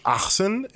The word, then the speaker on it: Ahsohn: It means it's an Arabic name and it's pronounced Ahsohn in Arabic.